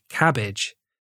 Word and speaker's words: CABG: A coronary artery bypass graft, which can be shortened to CABG, pronounced cabbage,